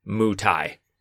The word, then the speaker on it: Muay Thai: However, the style of Thai kickboxing, called Muay Thai, and there's a little parenthetical pronunciation guide, it is Muay Thai.